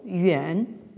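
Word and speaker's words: Yuan (远): Hein, Zhi, ici aussi, donc vous avez un terme qui d'habitude se prononce au troisième ton, Yuan.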